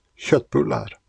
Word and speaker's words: köttbullar: I think I responded to this way back when, but the kotbulla refers to Swedish meatballs, as you correctly pointed out, but are pronounced köttbullar. Well, that's me trying to sound Swedish.